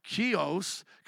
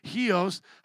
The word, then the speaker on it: Chios: The next day, we set sail from there and arrived at Chios, because I think the CH is a he, Chios. Chios, I keep looking at Jared. How would you pronounce it? Same way, like Chios.